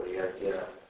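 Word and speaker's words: Yagya (यज्ञ): I don't know whether it was all, like, for example, JN is often pronounced GY, as in Gyan, or Yagya.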